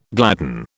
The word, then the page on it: gladden: British English